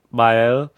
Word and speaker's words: Vael: And I think I pronounced it pretty well, because first I said Vael, and I heard them say Vael. And it's spelled V-A-E-L, but check our playlist.